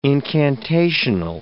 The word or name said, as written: incantational